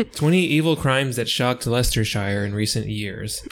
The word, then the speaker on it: Leicestershire: No, it's Leicestershire. Oh, but it's okay. L-E-I-C-E-S-T-E-R-S-H-I, like Worcestershire kind of. – Worcestershire. – It's Leicestershire.